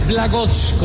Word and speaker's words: Blagoj: Se debe pronunciar Blagoj, como lo hizo Mauricio, pero nosotros en Colombia le decíamos Blagoj Vidinic.